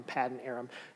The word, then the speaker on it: Paddan Aram: So I went to a few places, and most of them kind of land on Paddan Aram.